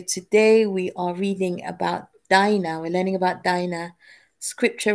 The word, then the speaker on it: Dinah: She lived about 1850 BC, and her name is Dinah. So it's pronounced Di as in Die and then Nah, and it means justice.